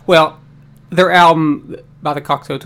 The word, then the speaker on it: Cocteau: But no, my 90s album is by a band called the Cocteau Twins. I even put the how to properly say this because I say it wrong all the time. Cocteau, like Cocteau.